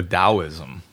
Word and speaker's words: Taoism: The founder of Taoism, or Taoism, it's actually pronounced.